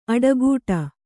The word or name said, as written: ಅಡೆಗಟ್ಟು